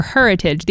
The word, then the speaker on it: Heritage: This Friday at Still Point in West Tisbury, there's an event called Heritage or Heritage. The emphasis is on the her.